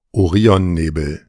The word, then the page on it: Orionnebel: German